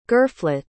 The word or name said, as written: girflet